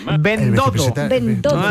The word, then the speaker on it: Bendodo: También hoy, Elías Bendondo... – Bendodo. Hay que pronunciarlo bien.